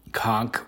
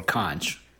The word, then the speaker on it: conch: So I looked up the pronunciation, conch or conch. Turns out either one, the fuck.